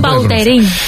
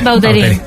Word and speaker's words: Vautrin: La original francesa, Vautrin. – Ahí pega un Vautrin. A mí me gusta Vautrin. – ¿Ves? Vautrin.